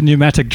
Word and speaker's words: pneumatic: I've bought a pneumatic drill. – Have you? – Yeah. – I like how the P is silent.